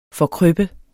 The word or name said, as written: forkrøppe